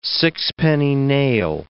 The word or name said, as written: sixpenny nail